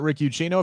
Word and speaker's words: Rick Ucchino: You can follow me at Rick Ucchino. If you're listening on the podcast, that is spelled UCCHINO. It's also RICK, not RIC like the flair.